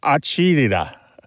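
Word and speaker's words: acidità: And it's pronounced something like acidita, acidita.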